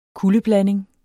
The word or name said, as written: kuldeblanding